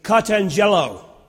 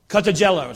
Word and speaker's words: katangelo: Show is the Greek word, I spell that phonetically, katangelo. Katangelo, it's like cotton jello.